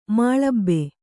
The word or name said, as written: ಮಾಳಬೆ